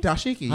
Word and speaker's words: Dashiki: So, if you want to see Oral in it, is it Dashiki? – Dashiki, yeah.